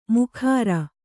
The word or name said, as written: ಮುಖರ